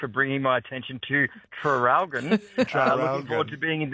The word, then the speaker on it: Traralgon: Seeing as you asked about the spelling of Traralgon, could you please pronounce the first R? – Oh, Traralgon. – Traralgon. – Traralgon? – Traralgon. – Traralgon. – Oh, yeah, and someone else, Maggie, says, don't we pronounce the first R in Traralgon anymore. Oh.